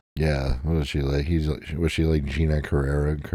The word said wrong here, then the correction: Gina Carano: Gina Carano.